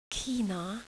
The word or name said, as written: China